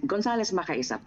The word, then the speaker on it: Gonzalez Makaysa: Before I proceed, Consul General, did I pronounce po yung last name po ninyo na Gonzalez Makaysa o is it Makaysa? – Gonzalez Makaysa, perfect.